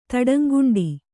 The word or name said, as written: ತಡಂಗುಂಡಿ